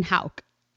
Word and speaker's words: Houck: And it's Colleen Houck. Did I say it right? Just to make sure. – Yes, you got it right.